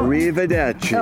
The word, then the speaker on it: arrivederci: Well, arrivederci.